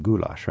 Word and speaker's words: goulash: Now, there's much more to uh, Hungarian cuisine than goulash, right? Yeah, of course. Goulash this is the right pronunciation, is one of our best and uh, most famous.